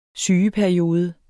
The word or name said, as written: sygeperiode